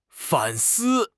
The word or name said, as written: 反思